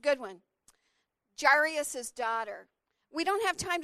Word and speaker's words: Jairus: So this guy named Jairus is really how you would pronounce it.